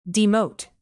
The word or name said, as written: demote